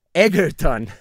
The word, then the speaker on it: Egerton: Sale Taron Eggard, el que hizo a eh, Elton John. Ah, ¿Lo dije bien? ¿Taron ¿qué? – Egert. – Ajá, Tyron Egerton. – Egerton, ah, ah ok. Taron Egerton, no lo voy a quitar, me vale madres, no sabía cómo se llama.